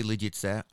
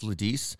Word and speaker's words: Lidice: Sure, it's Lidice, and in Czech you'd say Lidice. I've heard it when we learned about it in school here in the States, in American schools. I've heard it pronounced Lidice. I've heard people say Lidice. Lidice is the more common version that I've seen, the American version of that.